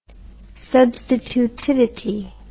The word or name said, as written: substitutivity